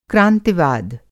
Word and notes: क्रांतिवाद: Hindi

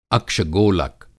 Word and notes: अक्ष-गोलक: Hindi

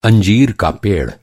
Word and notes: अंजीर: انجیر

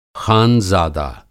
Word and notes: ख़ानज़ादा: خانزادہ

